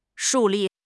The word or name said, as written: 树立